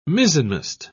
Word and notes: mizzenmast: British English

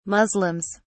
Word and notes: muslims: British English

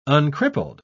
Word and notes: uncrippled: British English

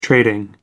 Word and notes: trading: American English

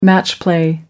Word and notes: match play: American English

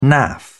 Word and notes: naff: British English

